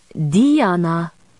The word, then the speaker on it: Diana: "Diana." – Isabeau said it with a long E rather than an I, and an emphasis on the first rather than the second syllable.